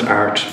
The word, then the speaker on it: Airt: Ask either in Irish, it's AIRT.ie, and art, it's pronounced art, but it's spelled AIRT, and that's an Irish word for a point on a compass, a direction or a guide.